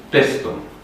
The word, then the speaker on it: Paestum: Și Paestum, Pestum.